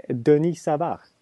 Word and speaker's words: Denis Savard: Eh, då har vi då Dennis Savard, han nummer 18. Mm. Eller Denis, Denis Savard.